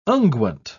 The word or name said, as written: unguent